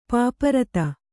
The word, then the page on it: ಪಾಪರತ: Kannada